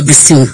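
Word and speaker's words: Bissue: And see, Honorable Mavis Kukua Bissu. – Honorable Mavis Kukua Bissu.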